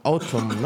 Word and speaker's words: Autumn: Autumn, ¿no? ¿Cómo se dice esto? Lo has dicho bien.